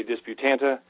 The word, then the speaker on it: Disputante: I am proffering that, as far as I can tell, when I say Disputante, everybody, all the locals, agree with me.